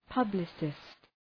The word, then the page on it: publicist: {'pʌblısıst}